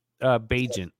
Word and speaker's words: Bagent: Bagent. It rhymes Bajan. With agent.